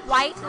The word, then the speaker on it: Whyte: It's pronounced white like the color, but it's spelled very differently. – Spell it. – W-H-Y-T-E.